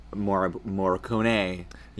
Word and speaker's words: Morricone: Morricone.